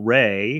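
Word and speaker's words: Rée: And there's this other guy, Paul Ray. Is that how you pronounce his name? – Yeah, that's how I pronounce it.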